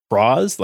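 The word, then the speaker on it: Cross-Z: Um, we get a new rider name, and so for Banjo, he's Cross Z, but it's pronounced Cross, like claws, I think. – Okay. – So cross the claws, cross.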